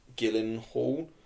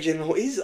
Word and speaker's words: Gyllenhaal: It is Jake Gyllenhaal, but I think there's a chance it's pronounced Gyllenhaal. – Either one.